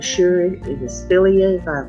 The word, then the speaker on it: philia: Philia.